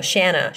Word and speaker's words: Shanna: We got a question from Shauna. No, Shanna. She says rhymes with banana.